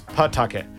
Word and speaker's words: Pawtucket: It's got Paw right in it. You just don't pronounce the W. If you're local and you from out there, we don't pronounce the W. It's Pawtucket.